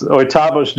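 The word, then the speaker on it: Oitavos: How do you say it? Oitavas? – That's pretty good. It's Oitavas Dunes.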